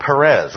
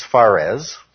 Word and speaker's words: Perez: The child who comes out first is called Perez, or in the King James, Phares. Okay. Just a slight variation in the pronunciation there.